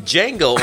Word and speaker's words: Django: Django underscore nerd, DJ NGO. The D is silent. Like always.